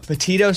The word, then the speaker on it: Petito: Did you guys catch any updates on the Gabby, you know, Petito story? How do you say her last name? What is it? – Petito story.